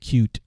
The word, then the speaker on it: Qt: It's spelled QT. I think it's pronounced cute.